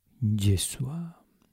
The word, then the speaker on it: Yeshua: En hebreo se lee fonéticamente Yeshua.